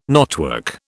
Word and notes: knotwork: British English

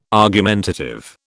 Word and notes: argumentative: British English

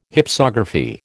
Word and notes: Hypsography: American English

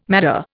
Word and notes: madder: Indian

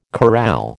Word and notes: corral: American English

